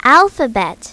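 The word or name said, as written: alphabet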